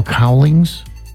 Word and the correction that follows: Cowlings: Am I saying his name wrong? – Cowlings? – Yeah, Cowlings.